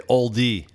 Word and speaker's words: Olde: Am I pronouncing that right? No. No one pronounces it right, right? Well, you can say oldie. Oldie? Yeah, like oldie English, like the Old, liquor.